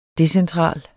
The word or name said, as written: DECENTRALE